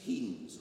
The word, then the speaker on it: Heens: Control experiments were made with other projectiles, as apple cores, cabbage runts, hats, roses, pumpkins, bullets, and ketchup, open brackets, heens, rhymes with beans, 1952, close brackets.